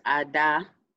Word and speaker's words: Ada: Yeah, so it's pronounced Ada. Okay. Like, it's really hard to pronounce correctly without the Igbo accent, but it's Ada.